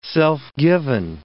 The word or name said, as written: self-given